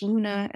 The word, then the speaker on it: Luna: Uh, my name is spelled M A R I A N A dash Luna, L U N A dot com.